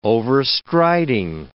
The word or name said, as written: overstriding